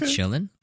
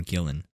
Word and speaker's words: Gillan: Is it Chillin or Gillan? – Gillan.